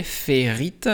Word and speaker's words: Ferrite: Aujourd'hui, on enregistre donc ce podcast avec l'application Ferrite. Ferrite, si vous voulez le prononcer en anglais, Ferrite,